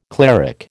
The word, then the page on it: cleric: American English